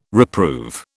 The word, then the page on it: reprove: British English